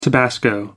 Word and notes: tabasco: American English

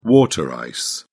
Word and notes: water ice: British English

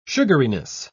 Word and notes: sugariness: British English

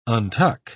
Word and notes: untuck: British English

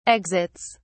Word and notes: exits: British English

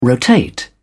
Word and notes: rotate: British English

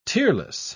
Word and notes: tearless: British English

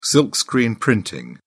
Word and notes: silkscreen: British English

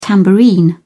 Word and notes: tambourine: British English

